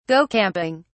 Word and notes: go camping: British English

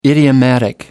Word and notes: idiomatic: American English

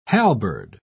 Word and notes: halberd: British English

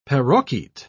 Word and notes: parroquet: British English

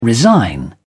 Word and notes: resign: British English

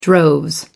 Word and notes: droves: British English

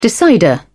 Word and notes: decider: British English

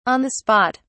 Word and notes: on the spot: British English

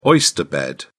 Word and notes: oyster bed: British English

